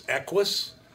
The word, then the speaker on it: Equus: Hyundai Equus.